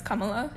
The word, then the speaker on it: Kamala: Oh, people say Kamala. – Oh, yeah, but it's Kamala.